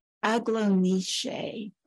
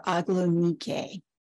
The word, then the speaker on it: Aglaonice: And so that paper that Nadia wrote was on Aglonice, or Aglonike. It is spelled A-G-L-A-O-N-I-C-E. And if you break that down, Aglo means bright or shining or beautiful. And the Nike is a variation of Nike, the goddess of victory.